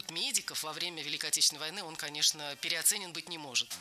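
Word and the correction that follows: переоценён: Переоценен. Неверно. Ударение в этом слове следует ставить на последний слог, в котором должна быть наша любимая «ё». Переоценен.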